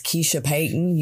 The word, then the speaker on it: Kiesha Payton: My name is Kiesha Payton.